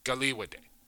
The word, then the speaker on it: Kariwate: So it's actually spelled, check this out, just to give you, so Galiwate's name is spelled K-A-R-I-W-A-T-E, but it's pronounced Galiwate.